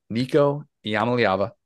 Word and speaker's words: Nico Iamaleava: Nico Yamala, oh God, I butchered it. I butchered it. I had to start over. I had to start over. I was butchering it. Nico Iamaleava he was still in eighth grade at time when Joe Milton is starting his college career.